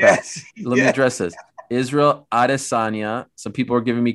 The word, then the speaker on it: Adesanya: I've always said, when you were telling me, Daniel, it's Israel Adesanya. – Adesanya.